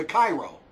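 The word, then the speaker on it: Chi-Rho: It's called the Cairo. Not Cairo, Egypt. But that's how you pronounce it from what the guys told me.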